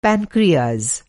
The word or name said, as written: पॅनक्रियाज